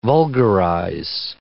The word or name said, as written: vulgarize